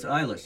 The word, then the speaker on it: Eilish: So it's Eilish. – There's yes. – an E-I-L-I-S-H.